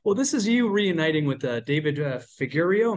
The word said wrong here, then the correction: Frigerio: Am I saying that correctly? – Yeah, Frigerio.